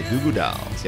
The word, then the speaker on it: Goo Goo Dolls: Yep, that's right, City of Angels and Busy Up the Girl by The Goo Goo Dolls. – By The Goo Goo Dolls. Yep. Although emphasis is on the goo. – The goo. The first goo or the second goo? – The first one.